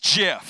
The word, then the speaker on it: GIF: Wrong. Wrong. It's Jif, okay? It's Jif, and I can prove it to you why, because the inventor of the word, or I guess the acronym, says it's Jif like the peanut butter.